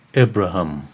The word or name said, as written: Abraham